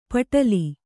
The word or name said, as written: ಪೆಟಲ